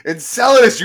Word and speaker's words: Enceladus: Enceladus, you moron!